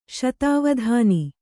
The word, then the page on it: ಶತಾವಧಾನಿ: śatāvadhāni